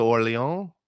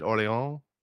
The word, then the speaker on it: Orléans: You live close to Orléans. Is that how you say it? Orléans? – Yeah, I mean, sure.